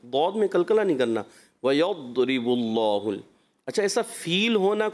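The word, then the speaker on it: ويضرب: ویضرب اللہ, یہ کلکلہ ویضرب اللہ, تو کلکلہ نہیں کرنا. اس کا تلفظ دوت کا لمبا ہے, صفت استطالت کی وجہ سے جب آپ وہاں سے زبان ہٹاتے ہیں تو ایسا محسوس ہوگا کہ کلکلہ ہوا ہے.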